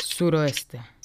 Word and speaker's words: suroeste: y bueno, después el, sudo, sudoeste, ¿así se le pronuncia? – Suroeste. – Sudo, suroeste, perdón.